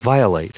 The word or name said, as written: violate